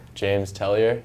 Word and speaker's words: James Tellier: James Tellier, JAMESTELLIER.